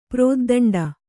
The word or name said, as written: ಪ್ರೋದ್ದಂಡ